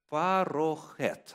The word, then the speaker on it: парохет: Термин «завеса» здесь, в этом отрывочке, является переводом древнееврейского слова «парохет». Обыкновенно в еврейских словах ударение ставится на последний слог. Вот. Некоторые произносят ее «парохет». Ну, как говорится, кто откуда, а везде говор разный.